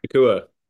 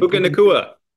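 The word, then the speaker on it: Nacua: Nakua. – I can't say his last name. – Puka Nakua. – Puka Nakua messes me up every time. But the Patriots met with Puka Nakua last year at the Senior Bowl.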